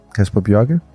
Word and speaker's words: Kasper Bjørke: Kasper Bjørke.